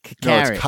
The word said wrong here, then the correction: Kakarot: No, it's pronounced Kakarot. – Oh. – As in a duty that's been sitting around too long.